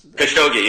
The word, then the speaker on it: Khashoggi: So the latest news is, and I'm not really good at pronouncing the victim's name here, Khashoggi. – Khashoggi, yeah.